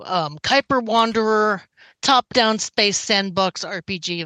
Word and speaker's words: Kuiper: Moonrange Games is working on a game called Cooper Wanderer. – Kuiper. It's an astrology thing. Don't worry about it. Nobody knows how to say it the first time.